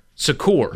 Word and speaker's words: Secours: Secours. – Yes. – The S is silent.